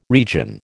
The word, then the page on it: region: American English